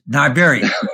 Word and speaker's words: New Iberia: New Iberia, it's Niberia. – Okay. – All right? It's just like Niberia.